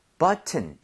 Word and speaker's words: button: 앞쪽에 강세를 엄청 주셔야 돼요. 그래서 여기 강세를 많이 줘서 button 이것도 돼요. 되는데 원어민들이 앞쪽에 강세를 많이 주면서 T를 그냥 거의 없어지게 만들 때가 있어요.